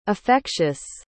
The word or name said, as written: affectious